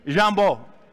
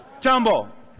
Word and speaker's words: Jambo: This is how you say hello in Swahili Jambo. Jambo.